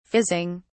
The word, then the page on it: fizzing: British English